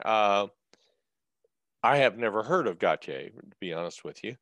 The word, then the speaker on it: Gotye: And so I thought it could very well be Gotye. – Okay. – It is not, it is Gotye, Okay.